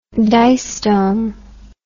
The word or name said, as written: distome